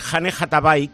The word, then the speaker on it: Anne Hathaway: Y Anne Hathaway, que es como se pronuncia correctamente en inglés, como mucha gente sabe, tenía plancha la criatura.